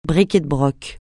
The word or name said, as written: bric et de broc